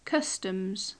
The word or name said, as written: customs